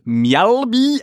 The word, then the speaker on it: Mjällby: I found a Swedish site called Mjällby, AIF, that's how you pronounce it in Swedish, in their home strip.